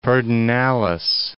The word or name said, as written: Pedernales